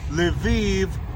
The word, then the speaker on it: Lviv: And a special shout out to the man inside on MGTOW TV for saying Lviv rhymes with Tel Aviv.